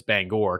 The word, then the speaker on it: Bangor: That's for all the people who live around here who don't know how to say it, but it's Bangor because there's an O in it, in Bangor, Maine.